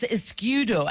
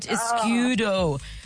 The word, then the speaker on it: escudo: It's Escudo, I think is how you say it. Escudo.